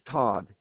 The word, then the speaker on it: Todd: Q-U-E. What was that again? – Q-U-E-S-H-A. – Okay, great. All right. My name is Todd, T-O-D-D, Bishop.